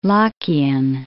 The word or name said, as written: Lockeian